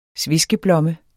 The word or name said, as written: sveskeblomme